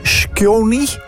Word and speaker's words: Skjoni: Shoki is, hoe spreek je dat uit, zeg? Shkoni, 31. Dat is heel moeilijk. S-K-J-O-N-I.